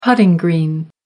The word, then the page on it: putting green: American English